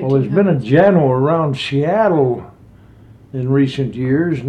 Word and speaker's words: Janeau: His name is Ed Jano, it's spelled like Juno, only J-A-N-E-A-U.